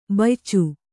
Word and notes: ಬಯ್ಚು: baycu

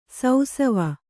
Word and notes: ಸೌಸವ: sausava

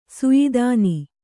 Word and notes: ಸಯದಾನ: Kannada

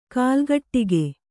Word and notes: ಕಾಲ್ಗಟ್ಟಿಗೆ: kālgaṭṭige; kannada

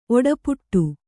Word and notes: ಒಡಪಾಟ: oḍapāṭa

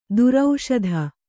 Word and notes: ದರ್ಶಿತ: darśita; Kannada

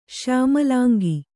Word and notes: ಶ್ಯಾಮಲಾಂಗ: śyāmalānga

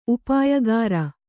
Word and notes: ಉಪಾಯಗಾರ: upāyagāra

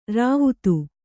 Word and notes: ರಾವ್ತ: kannada

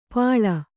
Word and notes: ಪಿಳ್ಳೆ: Kannada

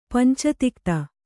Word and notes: ಪಂಚತಿಕ್ತ: panca tikta

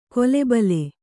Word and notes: ಕೊಲೆಬಲೆ: kole bale

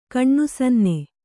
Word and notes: ಕಣ್ಣುಸನ್ನೆ: kaṇṇusanne

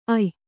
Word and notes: ಅಯ್: ay

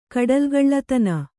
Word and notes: ಕಡಲುಗಳ್ಳತನ: Kannada

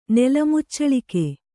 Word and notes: ನೆಲಮುಚ್ಚಳಿಕೆ: kannada

